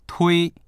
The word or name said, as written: tui1